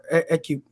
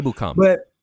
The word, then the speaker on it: Ebukam: Ekubim. Yeah, I always butcher his name. – Ebukam.